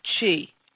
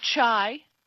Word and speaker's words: Qi: Uh, some people pronounce it chi, um, but the accurate thing is definitely chi not chai, chi. The ch sound for, is the sound for the q in China.